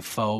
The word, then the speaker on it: pho: So it's spelled P-H-O. Some of you would say pho, but it's actually pho.